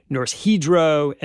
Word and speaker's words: Norsk Hydro: Just to note here for a second, we're saying Norsk Hydro and Alunorte, but these names are also sometimes pronounced Norsk Hydro and Alunorte.